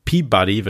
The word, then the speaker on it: PBDY: Und was ich noch dazu gepackt habe, worüber ich ganz gerne sprechen möchte, ist The State of Mind, ein Track von Peabody, wird er ausgesprochen, aber PBDY geschrieben, den er zusammen mit Annika von Exploded View gemacht hat.